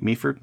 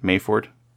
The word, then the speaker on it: Meaford: Meaford? Mayford? – It's Meaford. – Meaford. – Yep. – Yay. – Yep. You got it.